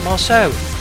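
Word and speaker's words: Marsheaux: Well, I'm guessing it's Marceau because they took it from the first syllables of each of their names, the two girls, Marianthe and Sophie. So we've got the Mar and the So. So we've got Marceau.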